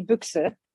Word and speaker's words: Buyx: B-U-Y-X. Wie oft wird das falsch ausgesprochen? – Ich habe so einen Standardsatz, den ich immer sage. Büchs wie die Büchse.